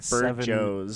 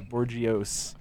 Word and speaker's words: Bourgeois: It's B-O-U-R-G-E-O-I-S. – That's right. – There's like Bourgeois. – Yeah, Bourgeois. – Bourgeois. – It's like half silent letters.